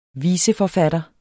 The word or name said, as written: viseforfatter